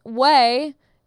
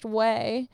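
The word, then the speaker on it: Ouai: Way. O-A-U-I, pronounced Way, is a great hair company started by Jen Atkin.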